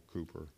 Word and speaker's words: Cowper: Cowper, Cooper, some people pronounce it that,